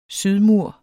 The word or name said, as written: sydmur